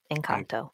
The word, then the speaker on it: Encanto: Yeah, let me say, let's see if I'm pronouncing this right. Encanto? – Yes, Encanto.